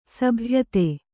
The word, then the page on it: ಸಭ್ಯತೆ: sabhyate